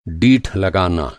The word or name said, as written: डीठ